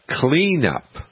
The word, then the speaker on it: KleenUp: But new shoots coming out of the ground, you can get cleanup. And it's spelled K-L E-E-N-U-P.